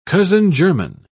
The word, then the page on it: cousin-german: British English